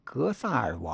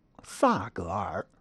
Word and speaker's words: 格萨尔: 其中比较明显的事例包括 2018 年 3 月全国人大会议闭幕时，习近平致闭幕词将西藏史诗《格萨尔王》归为中华文明，并将格萨尔错读成萨格尔，